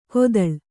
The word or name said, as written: ಕೊತ್ತಳ